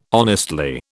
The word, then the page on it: honestly: British English